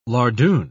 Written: lardoon

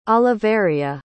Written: Oliveria

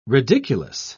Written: ridiculous

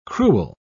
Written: cruel

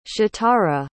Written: shatara

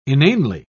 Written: inanely

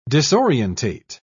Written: disorientate

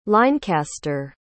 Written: linecaster

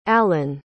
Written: alan

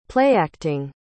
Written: playacting

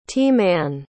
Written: t-man

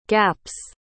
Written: gaps

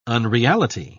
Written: unreality

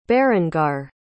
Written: berengar

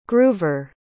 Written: groover

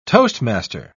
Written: toastmaster

